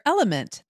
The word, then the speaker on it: LMNT: Sarah, we're welcoming our sponsor, Element. That's spelled L-M-N-T,